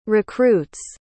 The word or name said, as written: recruit's